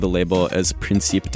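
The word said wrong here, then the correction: Príncipe: Discos. It's Principe Discos, the Portuguese label that specializes in Kuduro Batida, Taraxinha, and all kinds of other Portuguese dance music subgenres.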